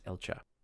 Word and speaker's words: Elcga: And I'm pretty sure I'm pronouncing that right, because it's spelled E-L-C-G-A. Since it's an Adirin-founded paladin order, I believe it's pronounced Elcha.